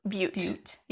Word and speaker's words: Butte: And I think we've looked it up and it's actually Butte.